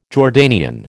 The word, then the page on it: Jordanian: American English